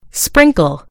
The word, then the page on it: sprinkle: British English